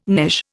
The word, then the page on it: knish: Indian